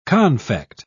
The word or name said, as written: confect